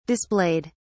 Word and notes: displayed: British English

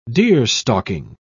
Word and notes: deerstalking: British English

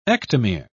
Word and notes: ectomere: British English

